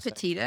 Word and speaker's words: Petito: How do we pronounce her last Petito. – Name? Petito.